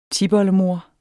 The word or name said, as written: tipoldemor